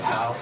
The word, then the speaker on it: Howes: And Sally Ann Howes. I think I'm pronouncing that right. – Howes.